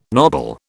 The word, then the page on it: knobble: British English